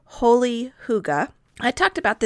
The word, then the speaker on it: hygge: It's actually spelled H-Y-G-G-E. It doesn't look at all like hygge.